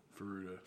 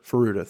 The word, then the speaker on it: Furuta: I don't know how to say it. Furuta or Furuta? – Furuta. – Furuta, thought so.